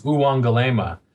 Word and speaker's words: Uwangalema: And to say it correctly is Uwangalema.